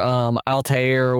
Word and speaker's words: Altair: Like the premise of the first game where Altair was, if I pronounced that correctly, give me a star. But yeah, close enough.